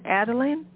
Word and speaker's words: Adalynn: And then how did you decide that, is it ADALYNN? Is that how you pronounce the movie? Adalynn, yes.